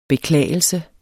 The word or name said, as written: beklagelse